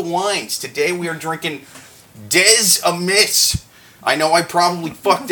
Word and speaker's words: Des Amis: I think it's pronounced Des Amis. – Des Amis. Yeah, see, that's the thing. They like consonants. They don't like saying them, though. You know, they like writing them, don't like saying them out loud.